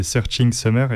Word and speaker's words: Searching Summer: c'est Searching Summer. Est-ce que ça se prononce comme ça? – C'est bien ça, oui.